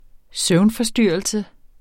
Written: synet

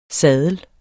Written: saddel

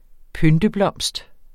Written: pynteblomst

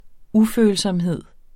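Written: Ufølsomhed

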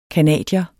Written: CANADIER

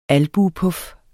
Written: albuepuf

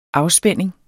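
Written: afspænding

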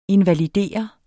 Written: invalidere

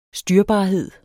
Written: styrbarhed

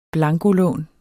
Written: blankolån

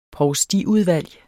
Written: provstiudvalg

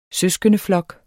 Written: søskendeflok

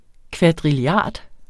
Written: kvadrilliard

